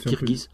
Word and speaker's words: Kirghiz: Donc c'est dur à prononcer, il faut dire Kirghiz.